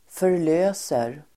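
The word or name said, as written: förlöser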